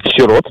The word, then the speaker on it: сирот: Во множественном числе данного слова во всех падежных формах ударение падает только на второй слог. Поэтому правильно говорить еще раз как? – Сирот.